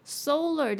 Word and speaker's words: solar: solar 的那个 lar 它可以念起来像是 ler 一样 solar system,